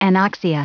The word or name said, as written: anoxia